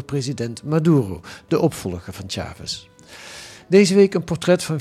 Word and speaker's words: Chávez: Een man, zeg je dat eigenlijk goed? Chavez? – Chavez. – Chavez, ja. maar de klemtoon wel op A, hè? Chavez.